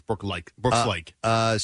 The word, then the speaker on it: Brooks Laish: Is that how you say his name? – Like him. It's Brooks Laish.